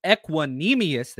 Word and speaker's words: Equanimeous: I've been working on his pronunciation. Equanimeous, there we go.